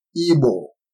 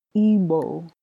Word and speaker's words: Igbo: All right, the first word is Igbo. – Igbo.